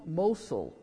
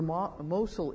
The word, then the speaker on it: Mosul: When we had so much early information on the war in Iraq, the city of Mosul, I think that's how you pronounce it, M-O-S-U-L, Mosul, was in the news every night.